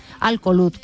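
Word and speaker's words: Al Kholood: Nada más llegar, a las 20 horas tendrá lugar el segundo encuentro amistoso que enfrentará a los albacetistas con el equipo de Arabia Saudí, Al Ohud. No se lo he dicho bien, Al Kholoud.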